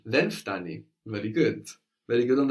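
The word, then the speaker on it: Then ftani (δεν φτάνει): Actually, in standard Greek, you will hear that N being dropped. The ftani. – De ftani. – De ftani. So it's not incorrect to say then ftani.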